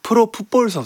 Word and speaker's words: 프로풋볼: 프로 풋볼 선수죠. 프로포폴 선수가 아니라 죄송합니다. 지금 많이들 오해를 하시는데 제가 그 발음이 꼬여서 프로풋볼 선수입니다. 프로포폴 선수 아닙니다.